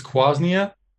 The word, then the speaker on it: quassia: So it's quassia and it hasn't been in there.